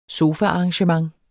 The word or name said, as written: sofaarrangement